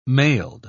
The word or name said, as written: mailed